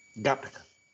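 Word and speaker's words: Gattaca: Gente, seguinte, é porque o Gataca, o Gataca, aliás, você que é o nosso consultor de cinéfilo, daí qual, qual é a pronúncia certa aí? Gataca.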